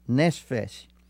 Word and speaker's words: nephesh: La palabra hebrea que en esta escritura se emplea por alma es nefesh, que se escribe N-E-P-H-E-S-H, que es la palabra hebrea.